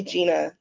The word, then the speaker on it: Jenna: My mom spelled my name Jenna, but it's pronounced Gina.